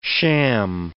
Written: sham

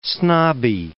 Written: snobby

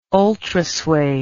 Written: ultrasuede

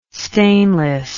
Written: stainless

